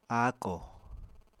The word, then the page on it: áakò: apatani